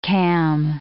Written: cam

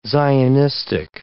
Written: Zionistic

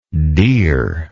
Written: dere

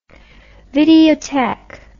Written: videotheque

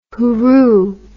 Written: hooroo

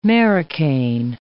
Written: marocain

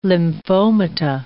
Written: lymphomata

